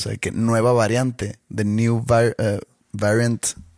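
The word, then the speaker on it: Nu: Pero bueno, que le iban a poner new, que una letra es N-U. – Uh-huh. – Se pronuncia new.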